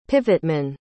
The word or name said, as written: pivotman